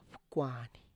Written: pkwani

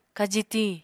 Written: kaji tiñ

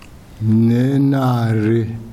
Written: Nenare

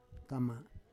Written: kama

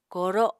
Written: kooro

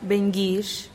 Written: bengix

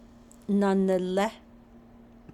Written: nanilé

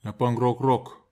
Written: napag rokrok

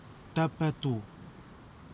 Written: tapatou

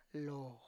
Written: loo

